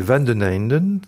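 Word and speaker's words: Van den Eynden: Mais jusqu'ici, vous l'avez tous les deux très bien prononcé, donc c'est Van den Heinden, tout simplement. C'est un nom flamand.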